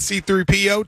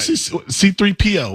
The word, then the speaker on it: C-3PO: C-3PO? Why do you say that? – How are you supposed to say it? – It's like one word, C-3PO. Not C-3PO. Not C-3PO.